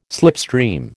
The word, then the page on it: slipstream: American English